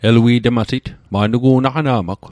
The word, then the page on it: elui'tmasit: Mi'gmaq